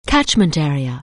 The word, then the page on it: catchment area: anglais